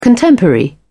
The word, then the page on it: contemporary: British English